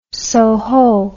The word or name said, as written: Soho